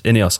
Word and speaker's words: Ineos: Ineos. That makes a lot more sense. Yeah. Yes, Ineos.